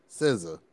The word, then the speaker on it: SZA: Before we continue, SZA. It's like the Wu-Tang Clan. RZA, the Jizza. It's just that syllable and Zuh.